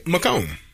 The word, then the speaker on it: Macomb: Wait, isn't it Macomb? – Macomb. – Macomb. – Yeah, I think the B is silent.